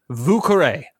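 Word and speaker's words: Vieux Carré: It's V-I-E-U-X-C-A-R-R-E, Vucaré, Vucaré.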